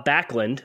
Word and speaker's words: Backlund: And then I have Bob Backlund.